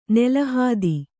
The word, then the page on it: ನೆಲಹಾದಿ: kannada